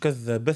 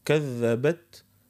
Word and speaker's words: كذبت: الهمس باش ما تظهرش ادغام، مش كذب ثمود، كذبت ثمود، فهمتني اخت ليلى؟ كذبت ثمود، نفصله، مش كذب ثمود كانها التاء ما تنطقش ان شاء الله.